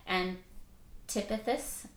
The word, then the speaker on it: Antipas: but Antipas, is that how you say his name? – Yeah.